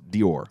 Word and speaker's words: Dior: Well, there's a perfume, and I believe it's called, it's spelled D-I-O-R. I think it's Dior. – Dior. – That's how you pronounce it. – Oh, Dyer. – Or Dyer.